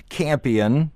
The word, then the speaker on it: Campion: Once again, James Campion, it's spelled like champion, drop the H.